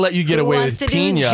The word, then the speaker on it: piña: Yeah, but piña, if it's an N with the squiggle over the top, that is pronounced with the yay. Iña.